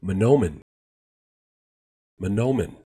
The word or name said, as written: Mahnomen